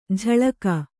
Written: ಝಳಕ

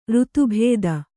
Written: ಋತುಭೇದ

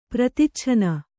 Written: ಪ್ರತಿಚ್ಛನ್ನ

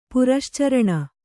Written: ಪುರಶ್ಚರಣ

